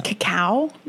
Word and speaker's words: cacao: I think it's cacao nibs. – Cacao. – Is the word you're looking for? – Cacao. Like, how do you spell it? – C-O-C-O-A. – Yeah, cacao. – Cacao. – Yeah. – What's the difference between that and cocoa? – I mean, it's the same, but it's just not how you say it.